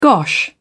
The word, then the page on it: gosh: British English